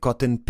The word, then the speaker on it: Cotten: Cotton Picking, un xogo de palabras co seu apelido que se pronuncia dun xeito moi semellante á palabra inglesa pro algodón, cotton, con dous os,